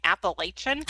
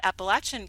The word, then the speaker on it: Appalachian: I have to keep reminding myself to pronounce it correctly because I'm like very like, Arizona girl going Appalachian.